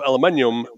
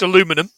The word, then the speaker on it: aluminium: The first model Walkman was a TPS L2, which was released in 1979 and originally made of aluminium but changed to plastic. – I think you'll find it's pronounced aluminum, but yeah, that's fine.